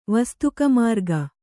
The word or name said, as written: ವಸ್ತುಕಮಾರ್ಗ